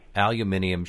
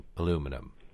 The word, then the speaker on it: aluminium: And as I would understand it, aluminium should be the correct pronunciation, but we changed the pronunciation to aluminum.